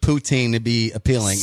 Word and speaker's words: poutine: And as far as, well, I don't want to say it wrong. I want to say Putin, and that's the president of Russia, and that's not right. Poutine.